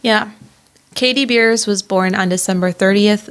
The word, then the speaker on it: Beers: He did say that right. It is beers, like what you drink in a can or bottle.